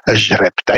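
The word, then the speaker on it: Jrepta: Pisze się przez J, a czyta się tak z francuska Żrepta.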